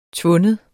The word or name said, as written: tvinde